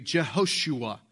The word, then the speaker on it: Jehoshua: His name is actually the best pronunciation for this would be Jehoshua, which means the Lord's salvation.